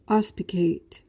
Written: auspicate

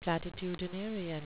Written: platitudinarian

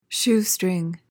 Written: shoestring

